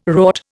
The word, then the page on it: wrought: Indian